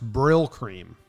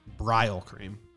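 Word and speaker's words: Brylcreem: Someone said in the chat it's pronounced Brill Cream, not Brile Cream.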